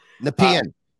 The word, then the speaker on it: Nepean: Nepean or Nepean, Ontario? – Nepean. – Nepean. All right, thank you.